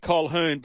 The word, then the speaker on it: Colquhoun: Big man, does it annoy you that Jesse Colquhoun, you pronounce it Colquhoun, but it's spelt C-O-L-Q-U-H-O-U-N.